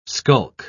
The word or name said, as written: skulk